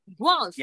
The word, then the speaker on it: Guelph: If we come to Gilep, right? That's how you say it? Or how do you say it? – Oh, well. – Guelph. – Yes. – Okay, Guelph.